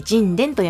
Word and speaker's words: 神田: 今回は、花の花酒造の神田と書いて神殿と読みます。